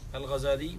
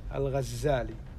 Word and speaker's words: الغزالي: الغزالي هو محمد بن محمد بن محمد بن أحمد، وهكذا يقال بتشديد الزاي. – الغزالي نسبة إلى غزة، لكن أكثر الناس ينطقون بالتخفيف الغزالي. نعم. – وقد روي عنه أنه أنكر هذا، يعني نسبة التشديد الغزالي. – وقال إنما أنا الغزالي بتخفيف الزاي.